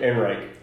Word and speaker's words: Ehrenreich: Alden Ehrenreich. How do you pronounce it? – Ehrenreich.